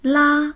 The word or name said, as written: la1